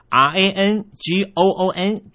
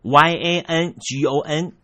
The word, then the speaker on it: Yangon: 佩哥改成了博固，仰光的拼写方式也从原来的 R A N G O O N 改成了 Y A N G O N，读音上面从 Rangoon 变成了 Yangon。从这读音的大大不同，听众朋友，你应该都能够感受到缅甸的拼音规范法造成了多大的改变。